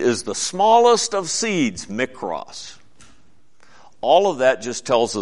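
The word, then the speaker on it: mikros (μικρός): That word is pronounced mikros, little ones. Mikros. We get the word micro from it.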